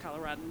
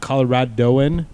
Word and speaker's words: Coloradan: Actually, I think she's a native Coloradan. But anyway, said that right, by the way. Not Coloradoan. She said Coloradan.